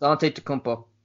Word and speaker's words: Antetokounmpo: It's Antetokounmpo.